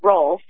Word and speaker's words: Rolfe: I was recently married, so it's now Rolf, R-O-L-F-E. – R-O-L-F-E.